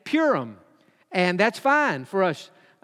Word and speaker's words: Purim: People pronounce this in English, Purim. I think that would be the most common, like P-O-O-R, Purim, Purim. Some would say Purim, like a cat purrs, Purim.